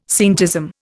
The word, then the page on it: scientism: Indian